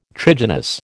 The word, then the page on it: trigynous: American English